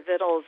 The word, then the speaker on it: victuals: We discovered that marketing flaw when a friend called me up and said that she had asked for vittles and someone had said, we don't have that book, but there's a new book called Victuals. So that is how it's spelled, V-I-C-T-U-A-L-S.